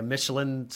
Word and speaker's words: Michelin: Wonder if it's the same consistent for what's the other one, like a Michelin star or something?